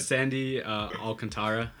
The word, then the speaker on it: Sandy Alcantara: I have Sandy Alcantara, is how you say his name, from the Marlins.